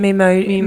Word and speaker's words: Memoji: It's like when I made my Memoji. Is that how you say it? Yeah, I guess Memoji? – So. – Memoji? – Memoji, yeah.